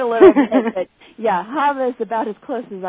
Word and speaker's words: Hava: Is it Hava? – Hava. I think if you want to be really technical, it's like Hava.